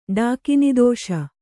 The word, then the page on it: ಡಾಕಿನಿದೋಷ: kannada